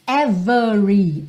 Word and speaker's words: every: Every sem o E do meio ser pronunciado, ok? Lembra que a gente já viu em outras músicas every, por uma questão de ênfase, tá? Então é possível que esse E apareça pronunciado quando eu quero enfatizar. Mas, via de regra, esse E não é pronunciado.